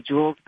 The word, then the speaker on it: Jools: J-O-O-L-S. My real name's Julia, but everybody calls me Jules. But I don't like the way you spell it, J-U-L-E-S.